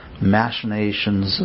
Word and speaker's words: machinations: We come up with all the countless machinations. Is that how you say it? Machinations? – Machinations. – Huh? – Machinations. – No, M-A-C-H-I-N.